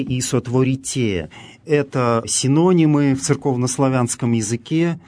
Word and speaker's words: сотворите: Здесь глаголы «сохраните», «сотворите», в которых мы слышим специфическое церковнославянское ударение, встречающееся именно в этих грамматических формах, второе лицо множественного числа настоящего времени, ударение на окончании.